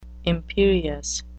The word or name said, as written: imperious